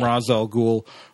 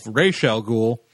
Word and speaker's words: Ra's al Ghul: On Arrow, when they first brought the character in, because no one had bothered to tell the people on the set how to pronounce the name, they had called him Ra's al Ghul for several episodes. But then when he actually showed up, he called himself Ra's al Ghul because a guy knows what his own name is.